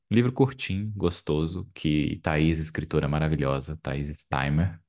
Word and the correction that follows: Steinbach: Acho que eu pronunciei certo. – Steinbach. – Steinbach. Steinbach. Perdão, Thaís.